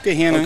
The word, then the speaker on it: Gahanna: Jerry Hammond from the Golf Depot up in, how do you say it? Gahanna? – Columbus. Gahanna. – Okay.